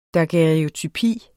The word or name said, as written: DAGUERREOTYPIERNES